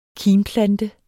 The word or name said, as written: kimplante